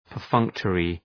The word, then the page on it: perfunctory: pər’fʌŋktərı